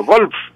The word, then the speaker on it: golf: Ese se llama golf, pero hay que pronunciar la F. No es golf, sino golf, con F.